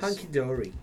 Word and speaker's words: Hunky Dory: Hunky Dory.